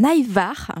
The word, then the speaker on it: ajvar: Un ajvar, je soigne ma prononciation parce que ça s'écrit A-J-V-A-R et c'est un confit de poivrons.